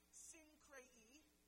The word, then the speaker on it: Cenchreae: At Cenchreae, that's how you pronounce that, Cenchreae, he had cut his hair there, but he himself went into the synagogue and reasoned with the Jews.